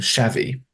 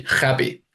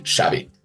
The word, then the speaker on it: Xavi: It must be Xavi. But as it turns out, it's not Spanish, it's Catalan, and the X is sh, so it is indeed Xavi.